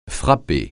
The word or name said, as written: frapper